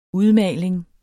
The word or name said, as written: udmaling